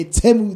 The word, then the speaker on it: Temu: Okay, Temu, that's the right pronunciation.